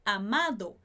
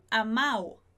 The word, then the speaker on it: amado: Por exemplo, o verbo amar, no particípio fica amado, e aí eles pronunciariam amao, amao, sem esse D.